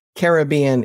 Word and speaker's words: Caribbean: Most of those natives do pronounce the word with emphasis on the first and third syllables. So Caribbean is the way to go here.